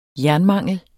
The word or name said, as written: jernmangel